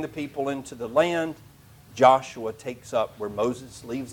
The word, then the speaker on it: Joshua: Joshua, which is sometimes pronounced Jeshua, or as you would have it in the Greek, Jesus.